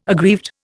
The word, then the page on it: aggrieved: Indian